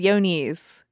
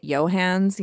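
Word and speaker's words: Johne's: So, the first one is yonies, which is spelled like John's with an E at the end. So, I was always calling it Johans, you know, like it was Amish. But it is pronounced yonies, and it is a paratuberculosis disease, which that is another name for yonies.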